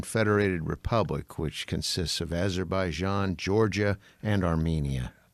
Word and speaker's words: Azerbaijan: Italy, France, Germany, Guatemala, Honduras, Mexico, India, Kenya, Cuba, Algeria, Azerbaijan, Philippines, Republic of Congo, Romania, Russia, Venezuela, Vietnam, and Zimbabwe.